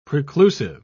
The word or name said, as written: preclusive